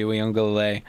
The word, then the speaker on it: Uiagalelei: Gotta go with DJ Uiagalelei.